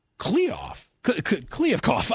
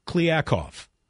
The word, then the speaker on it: Kliavkoff: MGM executive George Kliavkoff.